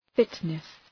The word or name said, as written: fitness